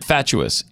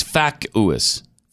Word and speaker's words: fatuous: I've said it fatuous, yeah. And that's wrong. – Yeah. – It's facuous.